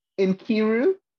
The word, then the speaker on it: Nkiru: No, Nkiru. Like, kind of like, I pretend it's I-N-K.